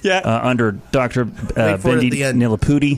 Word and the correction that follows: Bendapudi: Bendapudi. Gosh, see, I was so focused on just nailing her name correctly, and I butchered it.